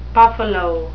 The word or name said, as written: buffalo